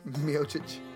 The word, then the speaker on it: Miocic: Now, given the fact that he was there, Miocic, is that how you pronounce his name? Miocic? – Miocic.